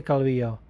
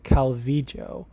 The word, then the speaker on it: Calvillo: Because if you didn't know, sometimes in Spanish, certain regions pronounce the double L sound differently, or just the Y in general, the Y sound. Like, Argentinians pronounce it with like almost like a sh sound, it'd be like Calvillo.